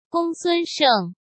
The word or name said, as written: Gongsun Sheng